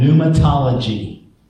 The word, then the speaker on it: pneumatology: Uh, the study of or Christian theology concerned with the Spirit is called pneumatology. And you spell that P N E U M A T O L O G Y, pneumatology.